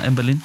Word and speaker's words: Amberlin: Du må korrigere mig, hvis jeg udtaler det forkert. Amberlin? – Ja, Amberlin.